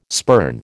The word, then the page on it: spurn: American English